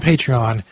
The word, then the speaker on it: Patreon: There's a website called Patreon. I guess is how it's pronounced. P-A-T-R-E-O-N.